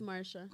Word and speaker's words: Marcia: Marsha? It's Marsha.